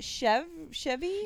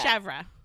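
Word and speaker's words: chèvre: Is it Chevy? – Yeah, that's goat cheese. – Okay, why do they drink goat cheese? How do you say that? – Chevre.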